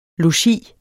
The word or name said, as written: logi